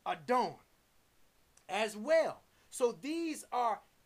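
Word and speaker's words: Adon (אָדוֹן): Strong's H113, Adon, Adon.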